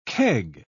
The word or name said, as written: keg